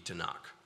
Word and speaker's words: Tanakh: So there would be vowels added when you say the word, so T-N-K pronounced would be Tanakh.